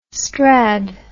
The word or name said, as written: strad